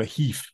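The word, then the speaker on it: GIF: I prefer the Spanish pronunciation of a heef, actually.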